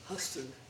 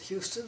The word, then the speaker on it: Huston: Huston, H-U-S-T-O-N, but pronounced Huston. – Mm. – Everybody else pronounces it Houston, but we pronounce it Huston.